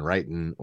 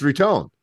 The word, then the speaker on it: Riton: I didn't say it right either, but I was saying writing or something else, and you were like, no, it's Ritone.